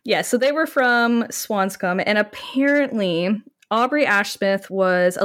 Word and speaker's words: Swanscombe: The Ash Smith family apparently resided in Swanscombe. Is that how you pronounce it? – Swanscombe. – Okay. – Yeah, so pretty much the way you did it.